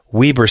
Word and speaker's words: Weber: That's not Weber State. That's pronounced Weber State, a AA school.